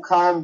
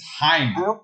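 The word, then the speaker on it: Chaim: You can't be like, oh, Heim Bloom, like, start spending money as if it's his money to spend. They brought Heim Bloom in. – Say his name right. – It's Heim.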